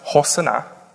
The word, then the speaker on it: Hosanna: But Hosanna is a Hebrew word. It's actually pronounced Hosanna.